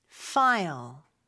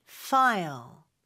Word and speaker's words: file: 파일이 아니라 영어로는 F로 발음해 주셔야 돼요. File. File. 이렇게요.